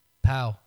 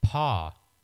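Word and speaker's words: Pau: We're not going to name who this person is, but we have a close friend that pronounced Jonah. – That pronounced it Paw. Gasol, and he got very angry when I tried to correct him.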